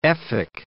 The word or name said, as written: Efik